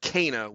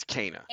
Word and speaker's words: Kena: It is pronounced Kena. We have that confirmed for us in the Kena. – game because they say her name. But also, the PR people made that very clear. They said it's Kena.